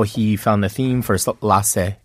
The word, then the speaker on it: Lahtze: So the show that he presented here is pronounced, and I'm sorry if I pronounce it incorrectly, is Lahtze, and that is Iranian or Farsi for moment.